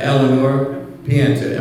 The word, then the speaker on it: Eleanore Pienta: Now, Eleanore Pienta, am I saying your name correctly? – Yes, you are.